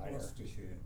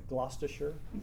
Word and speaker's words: Gloucestershire: Gloucestershire. – Gloucestershire.